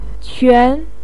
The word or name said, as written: quan2